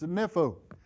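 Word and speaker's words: nepho (νήφω): The Greek word that is behind the word sober minded is a word that is pronounced nepho.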